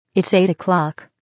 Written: eight oclock

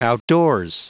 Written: outdoors

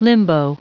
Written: limbo

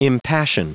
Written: impassion

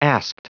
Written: asked

